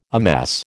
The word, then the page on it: amass: American English